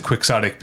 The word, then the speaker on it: quixotic: We know the Cervantes book is called Don Quixote, but the word that is derived from Quixote is Quixotic, and you pronounce it Quixotic because it's an American English, or it's an English language word. It's not a word. – Quixotic. – But it's not right, because it's not a Spanish language word.